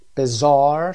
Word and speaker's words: Bizarre: Bizarre صدای زار هست.